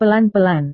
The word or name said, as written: pelan-pelan